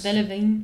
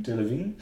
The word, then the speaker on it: Delevingne: Dagegen, ähm, Cara, wie spricht man den aus? – Delevingne. Delevingne.